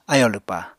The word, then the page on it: ayò lwpá: Apatani